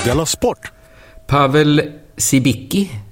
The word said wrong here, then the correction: Cibicki: uttalas det så? Han är MFF-are, va? Sibicki. – Det är faktiskt Civitski.